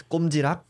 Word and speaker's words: Kkomjirock: Kkomjirock? – Yeah, that's right. – Yes, it's the pronunciation. It's Kkomjirock, but you can say like Kkomjirock or yeah.